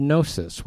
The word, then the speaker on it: gnosis: And the Greek word for understanding is the word gnosis with the silent G.